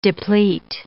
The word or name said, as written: deplete